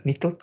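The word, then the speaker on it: 二都: 二刀って読むんだよね。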